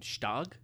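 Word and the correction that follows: Gstaad: Yeah, Stad. You don't pronounce the G.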